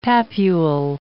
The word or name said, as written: papule